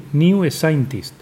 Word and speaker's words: New Scientist: ¿Cómo se pronuncia? New Scientist. Scientist. Scientist. New Scientist.